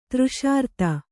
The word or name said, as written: ತೃಷಾರ್ತೆ